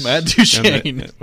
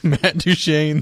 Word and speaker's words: Duchene: Matt Duchene. – We're not doing the long U. Okay. – Matt Duchene, the selection by Joe Rexroad in the competition.